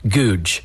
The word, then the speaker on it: Gouge: Now, his last name is spelled G-O-U-G-E, but it's pronounced Googe.